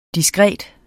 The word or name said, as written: diskret